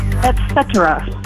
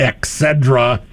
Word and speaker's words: et cetera: Et cetera. – Et cetera is correct, and you pronounced that correctly too. Mm-hmm. Et cetera. It's not ex cedra.